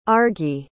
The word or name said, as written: argi